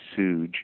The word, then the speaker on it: SUJE: Yes, on the 27th of 27th of February, there is a there's an event by the organization, I believe it's spelled S-U-J-E, Suge,